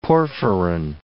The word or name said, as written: porphyrin